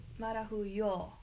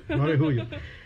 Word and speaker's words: Marahuyo: Jasmine Marahuya. – Yes, hello. – Did I say that right? – Yeah, emphasize the yo. – Marahuya. – Marahuyo. – Yo. Okay. Marahuyo.